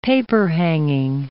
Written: paperhanging

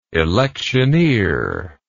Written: electioneering

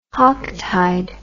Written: hocktide

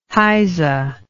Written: heyse

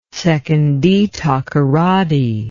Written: sekondi-takoradi

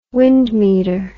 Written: wind meter

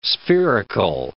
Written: spherical

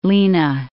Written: Lena

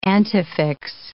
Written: antefix

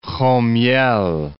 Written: Homyel'

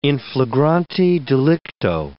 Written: in flagrante delicto

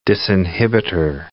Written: disinhibitor

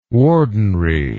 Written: wardenry

